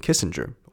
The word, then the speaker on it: Kissinger: And out steps Kissinger. – 那在这边你也有稍微注意到，比如说美国人念基辛吉，他们会念 Kissinger，我也会念 Kissinger。但是校长他是瑞典人，所以他就把那个 G 念成 G，呃，所以他他就一直念成 Kissinger，Kissinger。类似像这样子，他把那个 ger 放在一起，而不是念 ger。